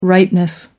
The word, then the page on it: rightness: American English